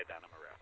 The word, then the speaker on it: Adana Moreau: And yes, that was an absolute correct way to say Adana Moreau.